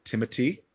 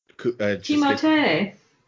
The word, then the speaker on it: Timothée: And a lot of it came from this unknown actor named Timothy Chalamet, which, by the way, did you know his correct pronunciation is Timothy? – Timothée. – Yes, yes. Just in case you did not. – I did not know that. – Yeah. The French.